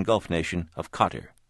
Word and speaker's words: Qatar: But officially, they want me to say it's sort of like guitar. We called the embassy. They said Qatar is fine.